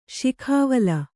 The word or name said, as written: ಸೌಗವಲ್ಲಿ